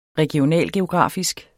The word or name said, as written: regionalgeografisk